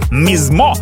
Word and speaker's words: Mizmo: Он француз, ну, раз он француз, корректнее, конечно, величать его Mizmo с ударением на последний слог.